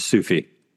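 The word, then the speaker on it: SCFE: What is the correct way to pronounce the acronym for slipped capital femoral epiphysis? – It's a Sufi. – You heard it here. Stop saying Skiffy, everyone.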